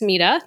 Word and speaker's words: Mita: Is it Maida? And I'll say, actually, it's Mita.